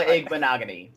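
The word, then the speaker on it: Igbinoghene: They did add Noah Igbinoghany. I don't know how to say his name. So I was just gonna let you keep going. Yeah, Noah Igbinoghany. That's how you say his name.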